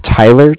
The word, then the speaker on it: Tyler: And my name is Tyler, T-Y-L-E-R. And I'm calling with Cardiff. It's spelled C-A-R-D-I-F-F, as in Frank Frank.